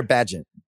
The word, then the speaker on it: Bagent: Tyson Bagent or Bagent? – Bagent, like agent.